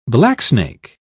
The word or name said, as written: blacksnake